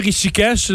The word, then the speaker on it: Rishikesh: oui, je prononce bien Rishikesh, le nom de la ville? Oui, c'est bien ça.